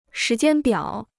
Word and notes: 时间表: shí jiān biǎo

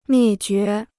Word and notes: 灭绝: miè jué

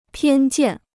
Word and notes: 偏见: piān jiàn

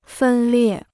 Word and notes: 分裂: fēn liè

